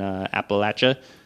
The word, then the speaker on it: Appalachia: I do know, actually, I know somebody, shout out Whitney, the Appalachian forager down in Appalachia. I said Appalachian forager, and then I said it right, Appalachia.